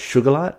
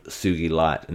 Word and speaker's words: sugilite: Well, my favorite crystal is one called sugilite, S-U-G-I-L-I-T-E. Now, some people, you may have heard this, pronounce it as sugarlite or sugarlite or something like that. It was discovered, this crystal, by a Japanese petrologist by the name of Kenichi Sugi, and this, it's called Sugi Light.